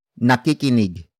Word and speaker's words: nakikinig: Nakikinig is spelled as N A K I K I N I G.